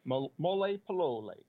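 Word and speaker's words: Molepolole: You talked about a place called, I'm not sure if I'm going to pronounce it properly, Mole Polole. – Mole Polole, yes.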